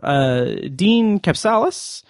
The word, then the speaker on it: Dean Kapsalis: Dean Kapsalis. – You did not mangle it. It was perfect pronunciation.